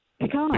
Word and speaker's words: pecan: Pecan. – Pecan? Oh, good. Oh, so yeah. Pecan is acceptable. It's acceptable in Alabama. Well, she's from Alabama. She should know.